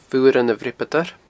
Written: Fuaran a Bhreabadair

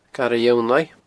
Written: Garadh Fhionnlaigh

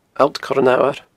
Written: Allt Coir an Fhamhair

